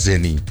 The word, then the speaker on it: Zeni: Jadi G itu dulu kalau sekarang bacanya Zeni.